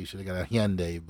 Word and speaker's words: Hyundai: It's definitely not a Honda. It's Hyundai. – It's Hyundai. – Hyundai. – Hyundai. – Hyundai. Yeah. You gotta say the teeth out. Hyundai.